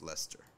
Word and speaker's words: Leicester: Of course, it's actually pronounced Leicester.